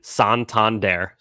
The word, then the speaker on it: Santander: Pronunciation, Santander. So we were right about that.